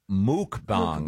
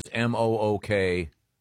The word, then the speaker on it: mukbang: It's actually pronounced muk, yeah. – Is it yeah. – muk? – Mukbang. – Yep. Oh, I thought it was mukbang. – Oh, yeah. – The pronouncer here is M-O-O-K. – Oh, okay. I've always heard muk. The people who do it, I've heard say muk. – It's spelled M-U-K-B-A-N-G.